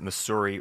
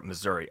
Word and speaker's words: Missouri: Then it took place a lot in the newspapers and editorials about should we pronounce it Missouri or Missouri. Obviously, Missouri sort of won in the public opinion there.